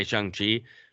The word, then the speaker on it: Shang-Chi: You have to say Shang-Chi. Just to all those people who are saying that the comic books they pronounced it Shang-Chi.